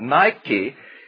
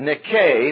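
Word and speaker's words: Nike (νίκη): Have you ever seen anyone, someone probably has them on tonight, if you've got on running shoes, you may have on N-I-K-E shoes, which we pronounce Nike shoes, or Nike sports equipment. Nike is the Greek word Nike mispronounced, N-I-K-A. It's a long A in Greek, so Nike would be the proper pronunciation, and it means winner, conqueror.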